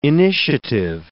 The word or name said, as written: initiative